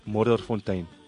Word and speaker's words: Modderfontein: D-E-R? – W-E-N-T. – W-E-N-T, Derwent Road, and that is in? – Modderfontein. – Modderfontein.